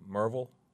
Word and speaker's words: Maryville: Somewhere in between Maryville and Merville, which is how the locals say it. We usually say Maryville College or Maryville College, yeah. – So it's kind of in between there.